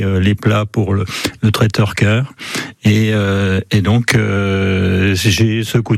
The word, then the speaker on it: Ker: Et c'est là où j'ai rencontré Marie-Louise Fay, qui est responsable de l'association Cœur. Alors Cœur, ça ne s'écrit pas C-O-E-U-R, mais K-E-R,